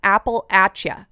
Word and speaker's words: Appalachia: I said Appalachia, and somebody from this area in the U.S. commented and said it's Appalachia, Appalachia, and then I looked into it, and that is the way that locals say it.